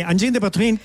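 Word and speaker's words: Angine de Poitrine: Oye, ¿y Anjin de Poitrain? ¿Lo pronuncié bien? – Sí, Anjin de Poitrain, que...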